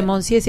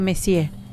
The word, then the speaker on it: monsieur: Y 46, y acá mi profesor de francés, Daniel Giovannini, me dijo que no se dice Monsieur, se dice Messier.